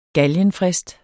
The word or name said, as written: galgenfrist